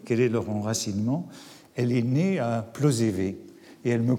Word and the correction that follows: Plozévet: corrige même, elle me dit qu'il faut dire Plozévette, que le, le T final euh, se prononce.